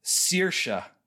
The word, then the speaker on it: Saoirse: So I think that's how you say it. Saoirse. It's like it's an Irish name. – Meaning it has all kinds of letters in there. – S-A-O-I-R-S-E is what I think the spelling is.